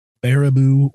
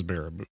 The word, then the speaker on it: Baraboo: So this one I think is the easy one on the list, but it could trip you up if you haven't heard the name pronounced before. So it's Barabboo. Yeah. And this is actually, yeah, it's just a little ways from here. So say it again. – Baraboo, Wisconsin. – Yes, it is Baraboo.